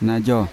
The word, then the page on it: najo: Yawa